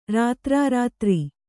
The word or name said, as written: ರಾತ್ರಾರಾತ್ರಿ